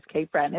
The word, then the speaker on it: Cape Breton: and we are talking about Cape Breton. Is that how you pronounce it? – Yes, yes, Cape Breton.